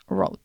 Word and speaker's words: Rhode: R-H-O-D-E Rode ¿vale? – Vale.